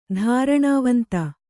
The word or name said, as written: ಧಾರಣಾವಂತ